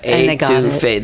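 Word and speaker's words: étouffée: They couldn't pronounce Etifi. – Right. – So we had to pronounce it capital A, number two, Fay, F-A-Y, A-2-Fay.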